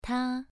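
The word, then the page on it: ta: tone 2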